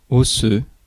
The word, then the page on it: osseux: /ɔ.sø/